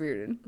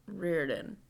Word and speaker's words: Riordan: Yes, Riordan. – Riordan.